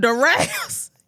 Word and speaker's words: Doral: So, Durals. – Oh, Durals. Yeah. Okay. – It's spelled D-O-R-A-L. – Yeah. – I guess I'm pronouncing it right. I don't know. – You're right, Dural.